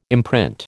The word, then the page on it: imprint: American English